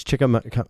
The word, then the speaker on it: Chicamacomico: Here is Chicamacomico.